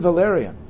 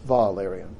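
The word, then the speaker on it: Valyrian: These are Valerion versus Valerion. – Pronounced the exact same, just because George loves that.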